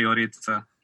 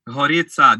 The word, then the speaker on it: Gorica: Živijo in po mojem prva naloga je zdaj v naslednjem tednu, da, da vaju naučim, kako se pravilno izgovori Gorica. Kako se izgovori? – Gorica, da ni, da ni H in da ni G, ampak je neki vmes. Gorica.